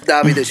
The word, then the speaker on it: Davide: You didn't pronounce my name correctly, not even one time, as a mistake. Guys, take some Italian lesson, come on. Chef Davide Cerretini, how hard can it be? Not Davida, not Davidi, come on.